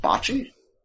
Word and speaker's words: bocce: It had an indoor bocce cage. I don't know how to pronounce that. Bocce? – Bocce, yeah.